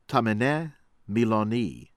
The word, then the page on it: Tahmineh Milani: American English